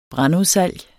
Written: brandudsalg

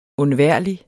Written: undværlig

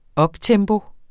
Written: uptempo

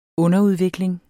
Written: UNDERUDVIKLING

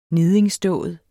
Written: niddingsdåd

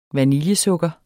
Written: vaniljesukker

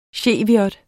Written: cheviot

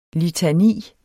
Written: litani

